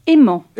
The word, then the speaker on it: Heman: Alors, est-ce qu'on dit Eman ou Aimant ? – Aimant. – Aimant. Bon, ça s'écrit H-E-M-A-N.